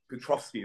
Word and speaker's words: Petrovsky: And at the time, the guy Robert, I can never pronounce this, Polish guy, Robert Petrolski, something along the lines like that. – He won't be out there, mate. I'm a Polak. – Yeah. – Petrovsky, there you go.